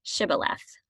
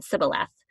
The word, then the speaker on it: Shibboleth: And if they said no, then they would have to prove it by saying Shibboleth. And if they couldn't pronounce the sh and they said instead Sibboleth, then they killed them because yeah.